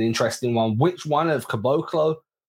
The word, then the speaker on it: Caboclo: K-U-H, Bo, emphasis on the Bo. And then Caboclo.